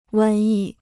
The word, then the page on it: 瘟疫: wēn yì